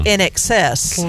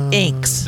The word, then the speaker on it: INXS: And I nearly drove off the road because it's in excess, not Inks.